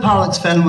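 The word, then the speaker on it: Pollexfen: We've got to pronounce that word very carefully. Pollocks. P-O-L-L, in case you think it's something else. But Elizabeth Pollocksfen was the daughter of a Sligo merchant.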